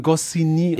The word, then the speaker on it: Goscinny: Ja, und vom kleinen Nick Goscinny. – Und so weiter. Goscinny. – Goscinny? – Den spricht man Goscinny aus.